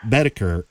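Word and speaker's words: Boetticher: Bedeker.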